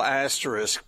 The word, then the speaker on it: asterisk: Well, I certainly think no asterisks is, did I say that right? – Asterisk. – Isk.